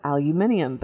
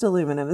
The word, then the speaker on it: aluminum: So I pronounced what we say aluminum as aluminium because that's what that's how it's pronounced and spelled. Well, then I'm telling that to my kids yesterday, and I'm like, let's see what the dictionary says. And so I pull it up well, the American dictionary has it spelled aluminum and pronounced aluminum.